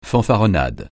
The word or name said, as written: fanfaronnade